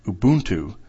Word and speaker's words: Ubuntu: The first is that Ubuntu, as far as I can tell, is pronounced Ubuntu.